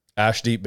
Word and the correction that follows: Arshdeep: Baines. – Yeah, Arshdeep.